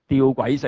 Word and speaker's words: 吊诡: 哦，去 25 节、这个呢在哲学里面称之为叫吊诡，我读哲学的时候呢，我老师发音叫的诡，后来啲人读到下这个字失传了，就不读的诡，是读吊诡 paradox，吊诡性、爱惜自己生命的就反而没了条命，在一世上恨恶自己生命的就要保存生命到永生，表面矛盾实际呢是没有问题的，这个叫 paradox，它那个的意义。